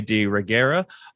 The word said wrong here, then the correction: de la Reguera: De La Reguera, like who we recently saw in Army of the Dead.